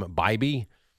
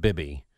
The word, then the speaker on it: Bibee: There was one sequence, I think they pronounced his name Bibee. Not Bibby.